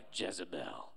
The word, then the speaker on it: Jezebel: The Bible says no one else was stirred up to do evil like Ahab was by his wife Jezebel. Why'd you say it like that, Pastor? Because it rhymes with hell, right?